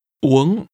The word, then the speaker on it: uống: Tôi muốn uống cái gì đó. – To drink is pronounced with a high, rising tone. – Uống.